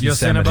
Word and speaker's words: Yosemite: Yes, Yosemite.